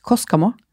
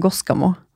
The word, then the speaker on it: Koskamo: Ja, det jeg har vel lært at det uttales altså, sånn på norsk, Koskamo. – Koskamo. – Men skal vi si det på finsk, for det finsk, så blir det Koskamo. – Ah. – For K blir G.